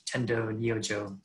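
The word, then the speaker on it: Tendō Nyojō: And the new abbot who took over was Qiantong Rujing, who in our Japanese lineage, we pronounce Tendo Nyojo Daiyosho.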